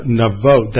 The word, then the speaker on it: Naioth: David is there with Samuel at this place called Navot. That's how it's pronounced. It's spelled N-A-I-O-T-H, and it is, he will leave there, so it's mentioned again in 1 Samuel 20, verse 1, but it's pronounced in the Hebrew, Navot, which is a little easier than trying to figure out how you pronounce a three-vowel diphthong there.